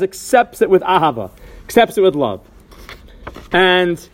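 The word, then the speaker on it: Ahava (אהבה): And in this tshuva, Rav Asher Weiss cites a Medrash Rabban Shir HaShirim, Amar Rabbi Acha, someone who recites the word Ahava by saying Eva, or the word V'ahavta by saying V'ayavta, or Ahava, Ayava, Ahavta, Ayavta.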